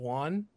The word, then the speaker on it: yuan: how is it pronounced? Yuan, yuan. They're stupid.